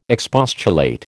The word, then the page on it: expostulate: American English